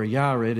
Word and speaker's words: Yared: Jared, or Yared, if you're going to say it in the Hebrew pronunciation, the Y for the J, he died.